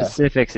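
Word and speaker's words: PC-FX: Do you ever pronounce PCFX, PCFX? – Pacifix? – Or Pacifix, yeah. – Pacifix is pretty much the best way.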